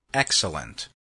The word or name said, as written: excellent